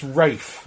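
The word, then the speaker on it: Ralph: Also, my name's Rafe, actually.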